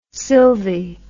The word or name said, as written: sylvie